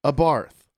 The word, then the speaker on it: Abarth: Weekly listener and lover of the podcast here, Nolan is awesome, but his pronunciation of Lancia and Abarth was annoying. Lancia is pronounced Lancia, and Abarth is pronounced Abarth.